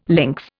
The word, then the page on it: links: Indian